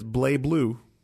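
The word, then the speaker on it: BlazBlue: But it's Blaze Blue is the correct way to say it.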